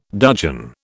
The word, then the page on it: dudgeon: British English